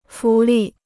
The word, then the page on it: 浮力: fú lì